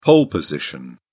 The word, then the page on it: pole position: British English